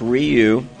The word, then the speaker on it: Ryu: We've been corrected on our pronunciations. – Zappy has corrected us. – Anyway, we've got it figured out now. It's Ryu.